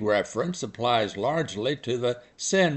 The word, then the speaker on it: Seine: Until the current Olympic coverage, I thought the Seine River winding through Paris to be pronounced Seine. Well, TV people, correctly I'm sure, are calling it Seine.